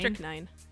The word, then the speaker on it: strychnine: Strychnine. – S-T-I... – No. – Strict. – S-S-T-R-Y-C-H-N-I-N-E.